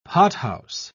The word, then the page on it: pothouse: British English